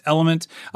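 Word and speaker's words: LMNT: So it means it's spelled weird. So it's L-M-N-T. There's no vowels, just Element.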